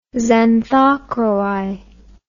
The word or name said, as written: xanthochroi